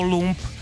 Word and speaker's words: Lump: Jmenuje se to Presidents of United States of America s názvem LAMP, ale čte se to LUMP. Ono to vlastně bylo takový jakože nadčasový. V 95. už věděli, že jim bude prezidentem, jejich bude LUMP.